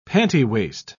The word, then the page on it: pantywaist: British English